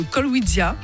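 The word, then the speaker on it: Kolkwitzia: Avec cet arbuste qui vient de Chine, le Colwydia, c'est comme ça qu'on prononce ? – Tout à fait,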